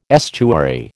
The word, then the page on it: estuary: American English